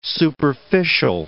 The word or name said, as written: superficial